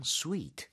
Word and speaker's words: suite: 영어에서는 그냥 sweet. – 네, 그리고 스펠링 주의하세요. suite. – 오케이. So, sweet 할 때, 정말 달콤한 할때그 sweet이랑 발음이 같나요? – 발음은 똑같아요.